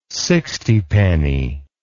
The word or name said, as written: sixtypenny